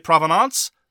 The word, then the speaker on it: provenance: And I have to note that provenance may be the only word with which I'm familiar where Americans pronounce it in the French way and I pronounce it in the English way. So if you want to say provenance, That's fine.